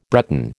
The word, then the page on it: Breton: American English